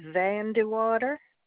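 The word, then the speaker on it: Vandewater: My name is Patricia Van de Water.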